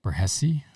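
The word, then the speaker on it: Borghese: B-U-R-G-H-E-S-E, and the G being silent. So Burghese or Burghese.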